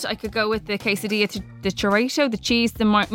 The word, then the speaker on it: quesadilla: Is it a quesadilla? – Quesadilla. Or as Nigella says, a quesadilla. – Well, I'm going to say it like Nigella, quesadilla.